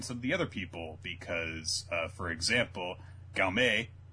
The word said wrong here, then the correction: Gaowei: Did I get her name right? – Don't ask me. – Gaowei. Ah, so close.